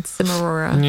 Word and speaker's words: Cimarora: He pronounces it Chimarora? – Yes. – Oh, okay. Yeah, I won't be doing that. I read it like cinnamon, Cimarora.